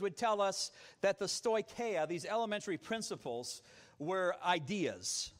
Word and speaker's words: stoicheia: Now, in Greek, the word for these elementary principles is stoicheia. Stoicheia. In English, you'd spell it S-T-O-I-C-H-E-I-A.